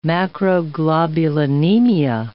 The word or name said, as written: macroglobulinemia